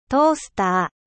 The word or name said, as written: grille-pain